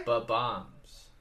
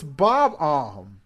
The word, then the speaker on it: Bob-omb: Yeah, it's spelled B O B dash O M B. So it's bomb bomb. Bo bomb.